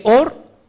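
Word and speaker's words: Or (אור): Luz en hebreo se escribe Aleph Bat Reish y suena como Or.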